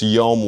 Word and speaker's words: yom: It rhymes with home. It's yom, Y-O-M.